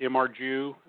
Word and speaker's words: Imgur: Well, actually, it was a guy on that website, I kind of pronounce it, Imgur, like an image website.